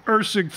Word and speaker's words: Erceg: What do you want to do here with Erkeg, if I'm pronouncing it right, Erkeg, Erkeg. – Erceg fighting Schnell.